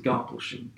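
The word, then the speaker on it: Garboldisham: Well, it's spelt Garbaldersham, but I pronounce it Garblesham coming from the...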